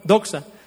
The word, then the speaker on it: doxa: Gloria, del griego doxa, con X, doxa, con acento en la O, doxa.